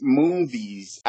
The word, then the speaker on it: Moonves: Lex Moonves? What's his name? – Yes, Moonves.